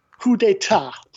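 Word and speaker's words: Ku De Ta: So my favorite place, and it's kind of a play on Madagascar's, troubled political history, is a restaurant called, and I'll spell it for you, KU-DE-TA. Coup d'etat, okay, which is a recognition that Madagascar's had more political coups than any other African country over there since independence.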